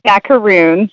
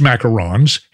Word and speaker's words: macaron: So most people call it macaroons just because it's a lot easier to say, but there's actually only one O. A macaron is the French almond cookie. A macaroon with two O's is actually a coconut cookie. Everybody calls it macaroons just because it's easy, but it's actually pronounced macaron.